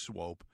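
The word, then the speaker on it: Swoope: And it's spelled Swoop, but it's pronounced Swope.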